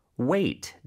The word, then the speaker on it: weight: Wait. W-E-I-G-H-T. – 네, wait. 기다려! 하고 같은... 발음이지만 스펠링은 다르죠. 그래서 GH가 숨어있다는 거 요거 잘 기억해 주세요.